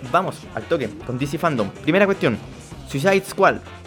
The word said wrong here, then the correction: Suicide Squad: Lo pronuncié como el culo. Hagámoslo de nuevo: Suicide Squad.